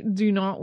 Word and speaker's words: Dunant: I'm not sure why that is the case, but in any case, we're writing it with the Y, but using the French pronunciation right out of the gate, just to confuse you. – Jean-Henri Dunant was born in Geneva, Switzerland on May 8, 1828 into a wealthy family, and he was the first of five children.